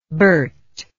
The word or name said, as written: Bert